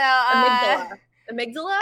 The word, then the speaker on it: amygdala: It's Amy G. Dalla. Amy G. Dalla. So, Amygdala? – Amygdala!